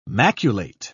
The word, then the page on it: maculate: British English